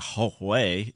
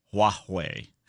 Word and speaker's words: Huawei: And it's actually not Huawei, it's Huawei.